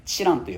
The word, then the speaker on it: 知覧: できれば受験が終わったら、あとは時間に余裕が生まれたら、鹿児島県にある知覧という場所に行ってほしいんです。知るに閲覧の欄と書いて知覧と読みます。これは特攻隊の方々の遺書がある場所です。僕自身は高校生の時に修学旅行の時にちょうど修学旅行で、知覧を、